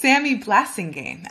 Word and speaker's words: Samie Blasingame: Uh, it's Samie Blasingame, actually. But I like Blassingame. That's fun too.